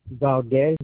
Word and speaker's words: Valdez: And if Valdez.